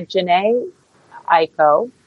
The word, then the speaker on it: Jhené Aiko: And the nominees are Quilombo by Jhené Aiko.